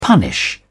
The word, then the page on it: punish: British English